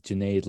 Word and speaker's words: Janae: So I always tell people it's Janae like lemonade.